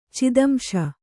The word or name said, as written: ಚಿದಂಶ